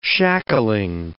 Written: shackling

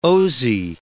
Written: Osee

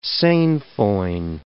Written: sainfoin

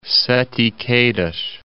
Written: Sete Quedas

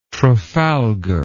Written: trafalgar

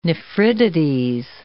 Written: nephritides

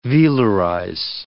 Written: velarize

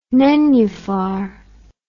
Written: nenuphar

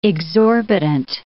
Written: exorbitant

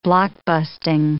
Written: blockbusting

